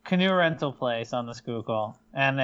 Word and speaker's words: Schuylkill: Nick Foles gets magical powers once he crosses like the Schuylkill River or whatever, however you pronounce it. – Schuylkill, yeah. Schuylkill. – And he's just like the magical trash-filled waters. – I tipped a canoe in the Schuylkill River.